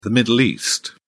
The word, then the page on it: middle east: British English